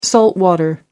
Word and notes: saltwater: British English

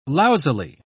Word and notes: lousily: British English

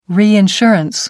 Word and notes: reinsurance: American English

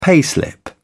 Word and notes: payslip: British English

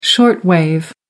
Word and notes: short wave: American English

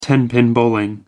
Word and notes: tenpin bowling: American English

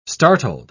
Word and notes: startled: British English

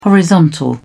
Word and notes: horizontal: British English

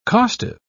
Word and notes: costive: British English

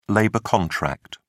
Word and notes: labor contract: British English

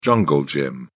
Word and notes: jungle gym: British English

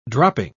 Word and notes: dropping: British English

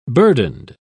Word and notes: burdened: British English

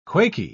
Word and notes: quaky: British English

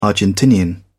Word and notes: argentinian: British English

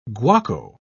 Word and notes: guaco: British English

